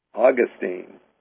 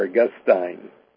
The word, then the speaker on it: Augustine: on, was shaped by Augustine, or Augustine, depending on how one pronounces that, and I know it's pronounced both ways.